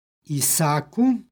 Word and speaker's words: Isaku: Može se u ovome primjeru izgovoriti jedno duže a, Isaku, a može se izgovoriti i sa dva a, Isaku.